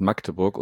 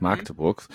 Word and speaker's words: Magdeburg: Das heißt Magdeburg und nicht Magdeburg. Weiß nicht, warum man das, wenn man nicht in der Region beheimatet ist, dann das A immer gerne ein bisschen langgezogen. Also Magdeburg.